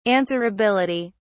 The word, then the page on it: answerability: British English